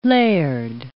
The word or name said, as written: laird